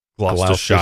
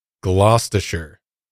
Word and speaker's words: Gloucestershire: I looked this Gloucestershire. – Up. Yes. Gloucestershire. – Don't say Gloucestershire. Gloucestershire. Yeah. Don't say Shire, man. As much as you want to say Shire.